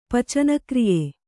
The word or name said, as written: ಪಚನಕ್ರಿಯೆ